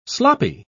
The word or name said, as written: sloppy